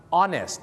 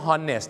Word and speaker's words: honest: แม่กลับมาพบกันอีกแล้วนะครับกับรายการคลิกนะครับแล้วก็วันนี้จะเป็นภาคต่อนะฮะจากตอนที่แล้วนะครับนั่นก็คือเรื่องราวของการออกเสียงหรือว่า English pronunciation นะครับเรามาเริ่มกันที่คำนี้กันดีกว่าครับคำว่าซื่อตรงหรือว่าซื่อสัตย์นั่นเองครับ honest คำนี้นะครับจะออกเสียงว่า honest ถูกต้องนะครับ honest นะฮะไม่ใช่ honest